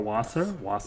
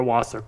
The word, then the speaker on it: Wasser: So what started all this was there was a rumor that Angie's lawyer, the legendary Laura Wasser, is that how you say it? I would say so.